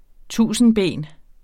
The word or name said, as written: TUSINDBEN